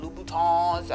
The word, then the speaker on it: Louboutin: But yeah, it's not saying that you can't have a nice pair of Jimmy Choo's or however you get down, Louboutins.